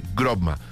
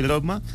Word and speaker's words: Grobma: Él es el CEO de Grobma. Yo lo dije bien, buenas tardes y bienvenido a 4.0. – Perfecto, sí señor, así es, efectivamente Grobma, está bien dicho.